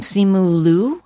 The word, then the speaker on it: Simu Liu: The actor that plays her fiance, I hope I don't mispronounce his name, Simu Liu, is that right? – Yep.